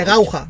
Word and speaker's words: Gauja: que es el Parque Nacional de Coucha, si no recuerdo mal. Un segundo, que lo tengo aquí. De Gauja. Se escribe Gauja.